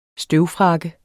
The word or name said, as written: støvfrakke